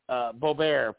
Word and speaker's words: Boebert: And, for instance, recently, Ms. Boebert, Boebert probably is how you should say her name, mm-hmm.